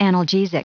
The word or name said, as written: analgesic